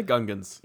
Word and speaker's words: Gungans: Yeah, the Gungans.